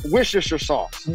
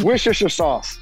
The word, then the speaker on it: Worcestershire: How do you say it? I never knew how to say it. – Worcestershire sauce. – Wouldn't say it again, please, sir. Say it again. – Worcestershire sauce. – I don't think that's it.